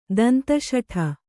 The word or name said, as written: ದಂತಶಠೆ